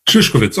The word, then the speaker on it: Šišković: Wie wird Ihr Name eigentlich richtig ausgesprochen? Šišković oder Šišković? – Šišković. – Okay, dann war ich verkehrt.